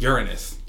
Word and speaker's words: Uranus: That's how the British people say it. Uranus. – That's the science. That's like the way you're supposed to pronounce it, apparently, according to Neil deGrasse Tyson.